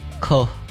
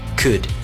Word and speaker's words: could: You said it like cut, not cut. – Cut. – Actually, there's no L cut. When you pronounce it. – Right. So the correct pronunciation would be could.